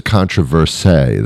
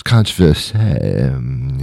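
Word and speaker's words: controverse: However, it's a controversé. That's the French pronunciation of controversy. It's controversé. Yeah.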